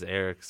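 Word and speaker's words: Erik: Well, my name is Eric, spelled with a K, which is the Scandinavian version of the name, which is where the name comes from.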